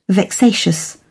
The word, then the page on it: vexatious: British English